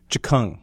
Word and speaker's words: Tchkung: T-C-H-K-U-N-G. – Chikung.